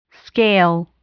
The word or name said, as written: scale